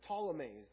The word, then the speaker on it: Ptolemies: The southern kingdom are the Ptolemies. The P is silent.